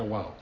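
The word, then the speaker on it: wow (ו): Recuérdense de la palabra wow, que es una, solamente es una W, pero se pronuncia wow.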